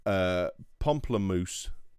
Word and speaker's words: Pomplamoose: Pomplamoose music on YouTube. That's Pomplamoose,